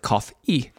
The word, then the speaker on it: COF&: C-O-F, cough. And in Espanol, the and is a E. It's a Y. So you pronounce it cough E.